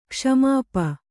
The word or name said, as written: ಕ್ಷ್ಮಾಪ